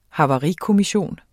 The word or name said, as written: havarikommission